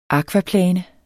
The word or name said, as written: akvaplane